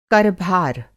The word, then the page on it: कर-भार: Hindi